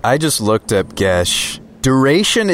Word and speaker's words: geas: Calm Emotions, Charm Person, Charm Monster, Command, Detect Thoughts, Divination, Enthrall, Geas, Glibness. – Gesh. – Gesh is how that's pronounced? Oh, wow. Okay. – Yep.